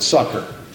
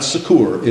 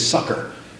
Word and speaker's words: succor: And that's the way you pronounce it, succor. It's not succor, it's succor.